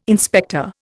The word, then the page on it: inspector: Indian